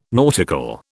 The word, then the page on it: nautical: British English